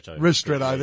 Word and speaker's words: ristretto: Now, I must have been, I didn't know what the Paris end of Collins Street was, but I do know the Strato Piccolo now. That's something I've learnt this morning. I like it because it's, is that how we pronounce it? – Ristretto, there you go.